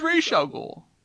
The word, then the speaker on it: Ra's al Ghul: It's Ra's al Ghul. It's, you can pronounce it either way.